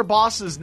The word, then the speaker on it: boss: Well, I think the problem is they're not used to hearing the correct pronunciation. Mm-hmm. And they don't know if you're saying bus, box, or boss.